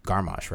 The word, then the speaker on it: Garmisch: And then you're also close to a big ski resort, Garmisch, right? – You pronounce that pretty right.